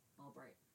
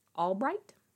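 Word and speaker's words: Ulbricht: B-R-I-C-H-T. – Albright. – Albright? – Yeah, it's like Albright, but...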